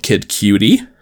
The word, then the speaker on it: Cudi: Cause so how it's spelled is Kid, but then C-U-D-I, Cudi. And she said, I didn't know that's how he spelled Kid Cutie.